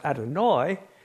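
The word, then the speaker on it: Adonai (אֲדֹנָי): The first one is pronounced Adonai, and this one is pronounced Adoni.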